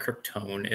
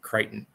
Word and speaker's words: Crichton: It's not Krypton. It's Crichton. That's how you say his name.